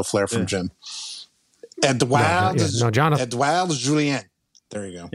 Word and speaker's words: Edouard: E-D-O-U-A-R-D.